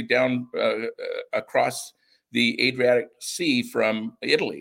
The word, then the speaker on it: Adriatic: Is it the Adriatic? – Yeah, Adriatic North Mission.